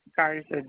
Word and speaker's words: Garza: Cynthia, C-Y-N-T-H-I-A. – C-Y-N-T-H-I-A. And the last name? – Garza, G-A-R-Z-A. – G-A-R-C.